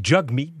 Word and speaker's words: Jagmeet: On l'appelle Jagmeet, parce que ça s'écrit J-A-G, mais c'est Jagmeet, donc c'est comme ça que ça se prononce.